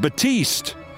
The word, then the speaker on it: Battiest: And Batiste, which is spelled Battiest, by the way.